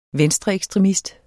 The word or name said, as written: venstreekstremist